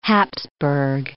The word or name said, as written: Habsburg